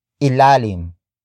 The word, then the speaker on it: ilalim: Word for word, it's sa ilalim ng kama. Sa ilalim ng kama.